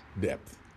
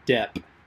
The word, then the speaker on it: Depp: Depth. I think it's pronounced Depp. Depth. No, Depp. Depth. No, it's Depp.